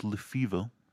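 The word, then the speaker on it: Lefebvre: So, and Cross, played by Jim, I think it's pronounced Lefevre. It's got a B in it there, but I kind of know that name from family things and stuff.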